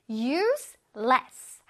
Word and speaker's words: use less: 근데 이거 발음 잘해줘야 된다. useless 하게 되면 필요하지 않은 아주 쓸데없는 이란 뜻이 돼요. 그래서 발음을 잘해야 돼요. useless 조금 띄어서 useless 하게 되면 덜 사용하다라는 뜻이 되겠습니다.